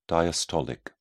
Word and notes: diastolic: British English

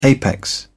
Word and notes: apex: British English

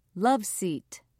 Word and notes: love seat: British English

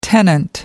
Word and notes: tenant: American English

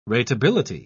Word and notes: ratability: British English

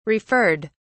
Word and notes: referred: British English

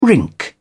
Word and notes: rink: British English

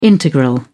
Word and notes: integral: British English

